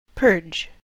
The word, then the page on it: purge: American English